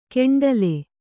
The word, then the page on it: ಕೆಂಡಲೆ: keṇḍale